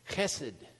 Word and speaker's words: chesed: It's chesed in the Hebrew. You're not saying that properly unless you spray the person in front of you.